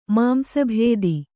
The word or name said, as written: ಮಾಂಸಭೇದಿ